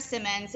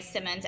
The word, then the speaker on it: Simmons: Yes, yeah, so on Twitter, it's Deandra Simmons, D-A-N-D-R-A Simmons, S-I-M-M-O-N-S. Deandra Simmons on Facebook, Deandra Simmons on Instagram. And my name is spelled D-apostrophe, capital A-N-D-R-A Simmons, S-I-M-M-O-N-S.